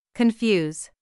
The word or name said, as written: confuse